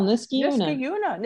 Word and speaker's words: Niskayuna: went to, and I'm going to mispronounce it, Niskayuna High School. – You didn't. You did well. Niskayuna.